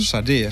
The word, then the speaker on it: Sade: Wie wird sie richtig ausgesprochen? – Sade. – Sade, siehst du, ich hätte es wieder falsch gesagt.